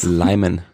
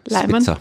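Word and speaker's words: Lyman: Und ähm, der Namensgeber, der Limenspitze, Leimenspitze, wie spricht man den Vornamen aus? – Leimen. – Leimen. Leimenspitze.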